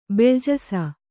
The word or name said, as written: ಬೆಳ್ಜಸ